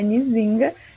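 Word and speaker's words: Nzinga: Mas é Nizinga e é homenagem à rainha Nizinga de Angola.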